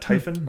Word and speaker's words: Typhon: Typhoon was a famous Greek monster. – Isn't that Typhon, not Typhoon? – Typhon, sorry, yeah.